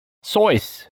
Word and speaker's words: Seuss: But did you know it's actually pronounced Sois, rhymes with voice?